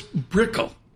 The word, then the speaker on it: Brickell: So it's Brickell. – Brickell. – Brickell. – Brickell, yes. – Not Brickell. – Exactly, not Brickell.